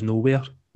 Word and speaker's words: Knowhere: Yeah, so on Twitter, I am at Knowhere, and it starts with a silent K. And for the podcast, it's at Where Is Knowhere. Again, the silent K in there.